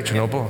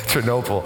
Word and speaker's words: Chernobyl: Did I say it right, Chernobyl? Okay, thank God, I got that right. Chernobyl.